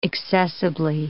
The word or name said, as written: accessibly